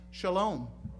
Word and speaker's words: shalom: And it's also interesting that the Jewish greeting of the day, in Hebrew it's pronounced shalom, means peace.